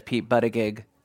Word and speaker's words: Buttigieg: We don't, you know, there's Sanders, there's Warren, there's Kamala Harris, there's Pete Buttigieg. Trust me, that's the way it's pronounced. Buttigieg. They have these placards at the convention just to pronounce his name.